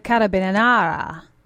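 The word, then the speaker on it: carabinieri: The carabinara. That's how they pronounce it.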